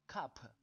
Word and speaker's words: cup: 在读这一个单词的时候，我们要注意 p 的发音是轻轻的 p，不能发成 p p。这个单词要读 cup，不能读成 cup cup，这是不对的。